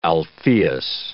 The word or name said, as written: Alpheus